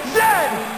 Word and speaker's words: Z: A toque is a hat, a Chesterfield is a coat, and it is pronounced Zed, not Z, Zed.